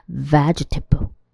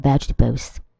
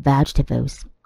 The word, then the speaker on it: vegetable: 正确的发音，它的第二个 e 那个音会被省略掉，也就是我们刚刚讲的母音的省略 elision 的发法。那它的 g 就紧接着 t 了，所以会念成 vegetable。 vegetable。 I like eating various kinds of vegetables. I like eating various kinds of vegetables.